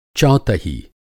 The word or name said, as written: चौतही